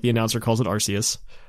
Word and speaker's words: Arceus: Like, I think in the Arceus movie, it's pronounced Arceus. – Correct. And it's with the hard K.